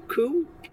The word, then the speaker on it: Kuh: Patric Kuh, Ko K U H, who wrote Last Days of Haute Cuisine.